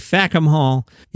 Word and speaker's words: Fackham Hall: It's called Fackham Hall, F-A-C-K-H-A-M. Make sure you pronounce it correctly. Fackham Hall.